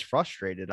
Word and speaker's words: frustrated: Yeah, you don't have an R in frustrated. – Frustrated. – Yeah, you just go straight F-U. – Wait, are you telling me it's spelled F-R-U-S-T-R-A? I don't say the R, do I?